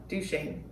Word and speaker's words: Duchesne: Right out there in eastern Utah of Duchesne.